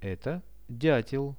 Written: eto diatel

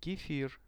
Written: kefir

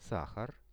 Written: sahar

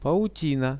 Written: pautina

